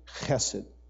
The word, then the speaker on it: Chesed: Chesed. Say it with me. Chesed. Okay, 1, 2, 3. Chesed. All right, now if you spit on your neighbor, you're saying it right, remember?